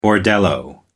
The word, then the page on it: bordello: American English